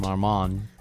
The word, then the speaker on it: Marmont: Marmont. – Marmont. – Yeah, the Marmont. T is silent, always in French. Yeah. Like Chevrolet, it's not Chevrolet.